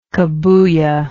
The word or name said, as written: cabuya